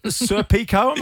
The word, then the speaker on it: Serpico: Serpico, am I saying this right? – Yeah.